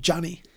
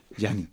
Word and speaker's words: Gianni: I enjoyed that we met the character Gianni and and they keep calling him Johnny. – Yeah, Gianni. – Johnny. – Johnny. – They're not pronouncing it Gianni at all.